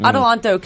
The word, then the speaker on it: Adelanto: It is in I think it's Adelanto. We've been pronouncing it wrong all this time. Mm-hmm. Adelanto, California. It's up by Bakersfield on the way to Vegas.